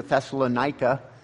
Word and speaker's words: Thessalonica: pronounced either Thessaloniki or Thessalonica.